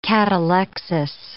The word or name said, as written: catalexis